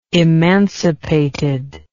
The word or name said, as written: emancipated